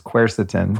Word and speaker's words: quercetin: It's quercetin.